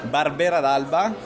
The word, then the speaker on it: Barbera d'Alba: Barbera d'Alba.